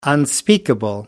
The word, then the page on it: unspeakable: American English